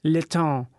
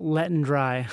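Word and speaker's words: Letendre: All right, His name was Paul Letond, and that's like, his name is pronounced like Letond. – Yeah. – It's L-E-T-E-N-D-R-E or something like that. – Mm-hmm. – And people, one time he got a call and someone was asking for Paul Letondry.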